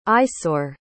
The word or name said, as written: isaure